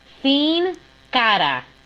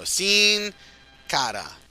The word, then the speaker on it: Sin Cara: Sin Cara. – There you go. Sin Cara. Talk about him in a moment. – Can I do it? Can I do it Alabama style? – Yes. – Sin Cara.